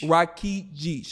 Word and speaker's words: Rakidzich: Rakidzich.